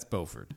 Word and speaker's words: Beaufort: Is it Beaufort or Buford? How do we say it in North Carolina? – North Carolina, that's Beaufort.